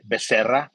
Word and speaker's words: Becerra: So again, my name is Hector Becerra. You can say it Becerra, which that's the right way to say it.